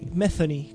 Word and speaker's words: Metheny: En enero de 1981, Aznar es convocado por el guitarrista norteamericano Pat Metheny, que nunca sé cómo se pronuncia. – Metheny, como una T con media como una F, para incorporarse a su banda.